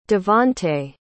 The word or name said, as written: davante